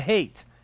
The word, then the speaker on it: Haight: The Hate. And that's spelled H-A-I-G-H-T. And I always found that ironic that the center of the hippie love movement is called the Hate.